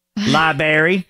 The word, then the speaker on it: library: Library. – It's a library. – It's a library. – Even adults say it, and I'm like, that's not how you say it. – There's an R in there.